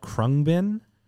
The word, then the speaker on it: Khruangbin: Right now, it's a group called Krungbin, spelled K H R U A N G B I N.